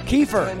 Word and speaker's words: Kiefer: Kiefer. – Whatever his name is. – It's with an Kiefer. – F. – Rhymes with reefer.